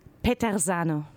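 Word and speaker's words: Peterzano: Petersano, wie spricht man das aus? Du bist italienisch? – Ich bin italienisch. Petersano.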